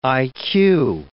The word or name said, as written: IQ